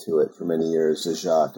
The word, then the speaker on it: Zagat: Yeah, which is how Zagat pronounces his name, right? – Correct. Yeah. We often tell people it's Zagat like the cat. Rhymes with cat.